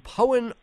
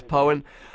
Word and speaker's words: Poyen: You actually said it right, man. That's rare. – Well, I overheard you telling our producer Joel how to say it. So I would have said Poyen, but yeah. Apparently it's Poyen.